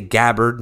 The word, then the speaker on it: Gabbard: I say Gabbard now because I used to say Gabbard and everyone was like, that's not how you say her name.